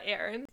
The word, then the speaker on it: Aron: A-R-O-N. Is that Aron? – Or yes.